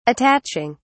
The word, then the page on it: attaching: British English